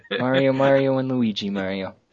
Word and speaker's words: Mario: And the voiceover guy, he says Mario. – Very clearly. – Case closed.